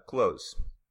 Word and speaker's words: clothes: Okay, so here's a little quick tip about the actual pronunciation of the word close. So if you notice, I didn't actually pronounce the TH sound.